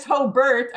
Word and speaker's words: Hobart: If you're not from Hobart, and it's Hobart of all things, because it's spelled H-O-B-A-R-T, but it's Hobart if you're an insider.